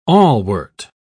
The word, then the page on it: awlwort: British English